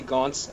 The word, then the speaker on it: Gaunce: Yeah, you can find me on Twitter at Jesse Gaunce. My last name is spelled G-A-U-N-C-E.